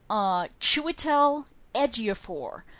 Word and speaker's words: Chiwetel Ejiofor: I told myself I was going to pronounce this right. Chiwetel Ejiofor, who is a British actor who's been in lots of things.